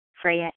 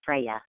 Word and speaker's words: Freya: Freya H. – Oh, say your name again. – F as in Frank, R-E-Y-A, Freya.